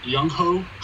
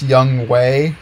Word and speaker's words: Younghoe: We have Younghoe Koo. – Oh, fuck. Actually, it's pronounced Young Way.